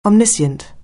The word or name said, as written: omniscient